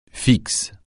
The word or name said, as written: fixe